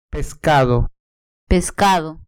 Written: pescado

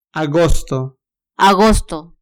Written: agosto

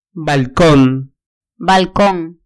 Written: balcon

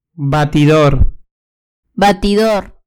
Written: batidor

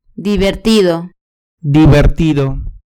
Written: divertido

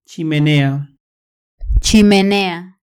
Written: chimenea

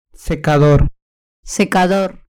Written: secador

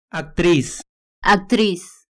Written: actriz

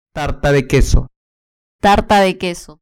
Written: tarta de queso

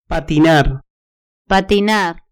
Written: patinar

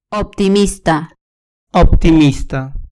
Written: optimista

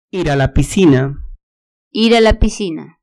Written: ir a la piscina

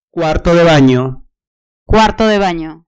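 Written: cuarto de baño

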